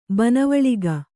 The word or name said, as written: ಬನವಳಿಗ